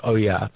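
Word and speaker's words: olla: You actually pronounce it Oya, but it's spelt Ola.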